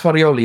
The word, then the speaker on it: Farioli: It's Farioli, yeah.